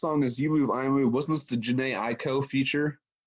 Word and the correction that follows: Jhené Aiko: I hate how you say that. – How do you pronounce it? I'm sorry. – That's everything. Jhené Aiko. You said, is that the Jhené Aiko feature? I was like, damn.